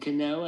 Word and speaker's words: Cano: So, and that's my boy Robinson Cano. I said it right this time.